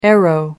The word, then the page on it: aero: American English